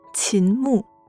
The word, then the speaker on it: 秦牧: 比如秦牧这个名字，姓是二声，名是四声。